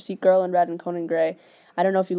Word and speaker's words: Conan Gray: Is that how you say his name? – Conan Gray. Yeah, you listen to him.